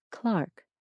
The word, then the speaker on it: clerk: Although this sound has mostly died out in the United States, it's still heard in England in words like clerk and derby, which are pronounced something like clark and darby. Other sounds were slightly different too. For instance, words that end in U-R-E were pronounced without the gliding Y sound, so creature, pasture, and nature were said creator, pastor, nater.